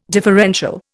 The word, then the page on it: differential: Indian